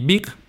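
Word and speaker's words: Beek: Y Big se escribe con doble E, como si escribieras book en inglés, pero con E, o sea, B grande, E, E, K.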